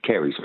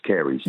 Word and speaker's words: caries: The word is caries, C-A-I. – No. Nope. Can I pronounce it for you? Yes, sorry. It is caries. Oh, yes, caries.